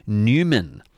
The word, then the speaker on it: Numen: Experience Albany's landscapes across billions of years in Newman. Now, not spelt like the town of Newman, it's spelt N-U-M-E-N.